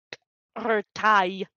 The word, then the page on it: Ertai: Phyrexian